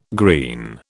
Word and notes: green: British English

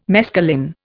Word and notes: mescaline: Indian

